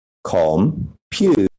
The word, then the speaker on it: computer: The difference is with the pronunciation of the word computer, computer, we stress the second syllable, right?